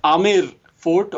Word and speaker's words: Amer: Unfortunately, it is spelled as A-M-B-E-R, so it is pronounced as Amber Palace, which is a very incorrect way of pronouncing it. – I have been saying that wrong. I did not know that. Okay. – Yeah, many of your guests are your guests also. – Oh, sure. – Yeah, it's not the amber color. Amber means orange, I think, right? Orange is mm-hmm. color in our English language, but it's pronounced as Amer Fort or Amer Palace, which is where many Bollywood Hindi films have also been shot at that palace.